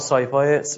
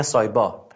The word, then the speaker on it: سایپا: بگید آقا سایپای، اونا میگن سایبا، پیام تلفظ نمیکنن به تلفظ میکنن، بیا آقا این سایبای صفر چند تومنه، خب چند چه قیمتیه، بگید به دلار بهتون بگه.